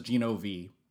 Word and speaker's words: Gino V: It is Mr. Sensational, Gino Vega, sometimes known as Gino V, because it rhymes with it's me, which is a little gimmick I lifted from a professional wrestler named, I think his name's like Paige Falconer, something like that.